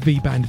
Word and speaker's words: VBND: Then we had, I think it's pronounced V-Band, V-B-N-D, No Other Feeling.